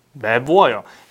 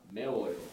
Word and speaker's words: 매워요: 맵어요. Sounds wrong, right? You feel that, right? – Yes. – Then it's wrong. Because it's tongue-twisted sound. We never want to pronounce that sound. So you figure out like, oh, I guess this is wrong. Then this is irregular. 맵다 has a B 받침, B bottom letter. So it becomes 워요.